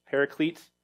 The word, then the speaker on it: paraclete: This is the Greek word, parakletos, paraclete, how we'll often pronounce it in English.